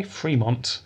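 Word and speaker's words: Frémont: I think you say his name that way, Fremont, but it's got a little accent of the Fremont.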